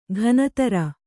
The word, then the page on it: ಘನತರ: ghanatara